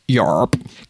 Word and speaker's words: YARP: The project, spelled YARP and pronounced YARP,